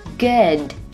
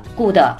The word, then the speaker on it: good: Have 和 a 连读成 have a have a good 里面的 d 不要发成了 good，那是非常中式的发音方式，一定要发成 good good。对，舌尖送到位，停顿一拍，可以不用发出声音来。